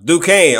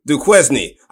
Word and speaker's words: Duquesne: Uh, Duquesne. Oh, so that's how you pronounce that. I was thinking Duquesne.